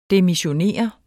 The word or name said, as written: DEMISSIONER